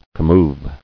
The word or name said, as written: Commoving